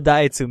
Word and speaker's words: Daihatsu: Knowing you and knowing that you love driving the, I'll butcher the name, but the Datsun, the midget. – Daihatsu like midget. – The Daihatsu midget.